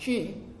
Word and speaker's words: chi: But actually, the interesting thing is pronounced chi, okay, but it is. I mean, we go chi.